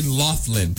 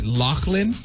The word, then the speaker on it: Loughlin: In 39 years of my life, I've thought that it was Lori Loughlin. Same. And apparently you pronounce it Loughlin. – Yes. – L-A-U-G-H. – L-O-U-G-H-L-I-N.